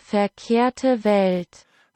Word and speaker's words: Verkehrte Welt: The name of the track that we're going to listen to translates to Wrong World, or in German it is pronounced Verkehrte Welt.